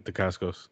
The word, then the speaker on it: Dacascos: I don't know how to say his name. But, like... – No, that's fine. That's how I say it, Dacascos.